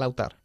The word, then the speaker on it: altar: La pareja se arrodilló delante del altar. – The couple knelt down in front of the altar. – The altar se pronuncia, ¿no? Alta. Altar.